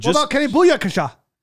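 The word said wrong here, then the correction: Yeboah: It's Yaboa. It's pronounced Kenny Yaboa, Mississippi.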